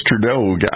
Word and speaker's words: Trudeau: And I thought it was, that's how you pronounce it, but it's Trudeau, I guess. It's kind of like Scott Ledoux, the boxer from the 80s, but it's Justin Trudeau, right?